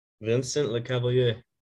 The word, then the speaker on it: Vincent Lecavalier: But I've seen it twice now, that there's talks that Kent Hughes wants to bring in Vincent LeCavalier, and I probably said it wrong. Sorry. I banged it. – Vincent LeCavalier. Yep. – There you go.